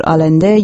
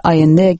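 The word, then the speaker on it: Allende: برای خیلی از شما شنوندگان رادیو فردا، اسم کشور شیلی با اسم سالوادور آلنده یا اونطور که خودشون تلفظ میکنن سالوادور آینده گره خورده.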